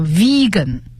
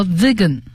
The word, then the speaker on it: vegan: 那么这个素食主义还有一个英文的词叫 vegan，它有三个读音啊，vegan 和 vegan 和 vegan，它是很严格的素食主义者的意思。就是有的人呢是鱼蛋素，就是吃鱼吃鸡蛋，或者是呢奶制品它是吃的，但是呢这个 vegan 来讲呢，它是任何动物的沾边的食品它都不吃。